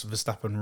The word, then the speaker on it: Verstappen: The fact you didn't pronounce Verstappen right once as well. – Why, what did I do wrong? – Double F instead of double P.